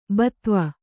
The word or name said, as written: ಭೂಧವ